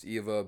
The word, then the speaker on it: EVE: It's spelled Eve, pronounced Eva, but stands for Extraterrestrial Vegetation Evaluator.